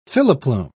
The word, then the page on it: filoplume: British English